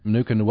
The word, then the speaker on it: Manookin: Uh, so, Kiri Manookin, welcome to the program.